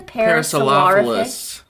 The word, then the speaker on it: Parasaurolophus: Parasaurolophus.